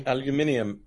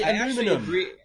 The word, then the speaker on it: aluminium: Aluminium. – Americans say aluminum. – I say aluminum, but I actually agree with you guys. It really should be aluminium. That's the way it sounds.